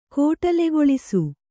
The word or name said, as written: ಕೋಟಲೆಗೊಳಿಸು